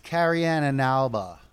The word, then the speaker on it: Carrie Ann Inaba: It's Cheryl Underwood, Corey Ann Inabo. Oh, she's from Dancing with the Stars. I know that because of my grandma. – It's pronounced Carrie Ann Inaba.